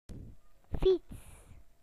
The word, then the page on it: bicycle: British English